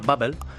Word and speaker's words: Babbel: Hay una aplicación para aprender idiomas que se llama Babbel.